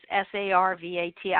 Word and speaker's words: Sarvat: My name in Arabic is Sarvat. I don't spell it that way. I spell it Servette, Servet, because I also grew up in London and Montreal.